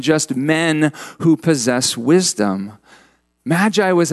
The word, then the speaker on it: magi: Well, Matthew uses the Greek word magoi for them. We pronounce it magi.